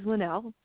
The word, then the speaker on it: Lynelle: My name is Linnell. – Linnell, that's what I thought. You spell it L-A-N-E-L-L-E? – It's actually with a Y. It's L-Y-N-E-L-L-E. – L-Y-E-L-L-E. – L-Y-N-E-L-L-E, Linnell. – Okay, L-Y-N-E-L-L-E.